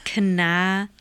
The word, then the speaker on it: cannabinoids: It's cannabinoids.